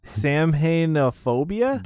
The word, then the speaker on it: Samhainophobia: And it is I'm gonna try and pronounce this. Samhainophobia? – Got it. That's it.